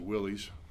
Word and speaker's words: wiles: And in order for that to be effective, we must put on God's armor, so we're able to stand against the wiles, that great KJV word. Some pronounce it willies.